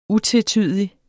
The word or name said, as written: utvetydig